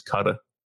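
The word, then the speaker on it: Qatar: And they educated, and they said the pronunciation is Cutter.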